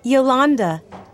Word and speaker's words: Yolanda: Yolanda. – Can you spell that, please? – Y-O-L-A-N-D-A. – And your last name? – C-A-S-T-I-L-L-A.